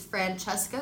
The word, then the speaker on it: Francesca: My Instagram is my first and last name, which is Francesca Grazzi.